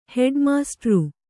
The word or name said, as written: ಹೆಡ್ಮಾಸ್ಟ್ರು